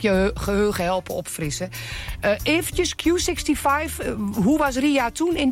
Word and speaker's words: Q65: Het is een nummer van wat we gaan draaien van Q65.